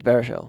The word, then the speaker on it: Baruchel: How do you pronounce his last name? – I'm pretty sure it's Baruchel.